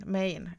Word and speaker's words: Maine: Uttalas det Maine eller mine? Maine, ja.